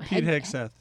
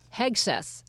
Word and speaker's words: Hegseth: Pete Hegseth. – Hegseth.